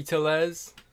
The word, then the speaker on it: Tellez: And then Tellez, or what's his name? How do you say it? – Rowdy Tellez.